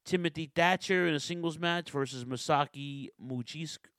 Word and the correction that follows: Mochizuki: Muchizuki, very nice. It's Muchizuki, right. Or Makazuki. It's Mochizuki.